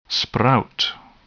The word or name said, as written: sprout